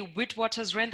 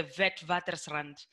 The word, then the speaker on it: Witwatersrand: okay so we say witwatersrand that would be the english and then the dutch equivalent would be like the vetwatersrand